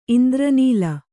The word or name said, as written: ಇಂದ್ರನೀಲ